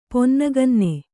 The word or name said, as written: ಪೊನ್ನಗನ್ನೆ